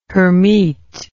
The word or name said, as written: Hermite